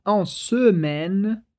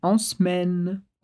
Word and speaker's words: en semaine: Perceba que a gente poderia pronunciar en semaine, mas é mais comum que a gente corte esse E para dizer en semaine.